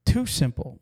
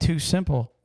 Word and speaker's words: TuSimple: Too Simple, which is spelled T-U-S-I-M-P-L-E.